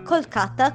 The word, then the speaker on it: Kolkata: e la prima tappa che raggiungeranno insieme anche a questa ragazza sarà proprio Calcutta, che come abbiamo detto prima dal 2001 si chiama Kolkata, con una pronuncia più vicina alla lingua bengalese e un po' più lontana a quella occidentale dei tempi del colonialismo.